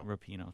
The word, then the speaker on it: Rapinoe: Megan Rapinoe, I believe that's how you pronounce her last name, the former U.S. women's soccer player. Did I pronounce that right? – Rapinoe, thank you. Rapinoe.